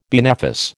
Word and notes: benefice: American English

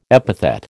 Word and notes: epithet: American English

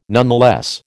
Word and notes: nonetheless: American English

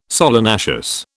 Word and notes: solanaceous: British English

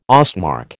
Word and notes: Ostmark: American English

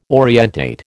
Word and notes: orientate: American English

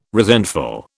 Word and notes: resentful: British English

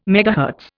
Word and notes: megahertz: Indian

